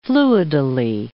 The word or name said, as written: fluidally